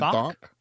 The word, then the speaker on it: Thok: And how do you say her last name? The drill sergeant lady. – Thok. – I heard something. What? – Isn't it Thok? – Thok? – Thok. Thok. – Like Spock Thok.